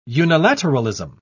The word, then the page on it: unilateralism: British English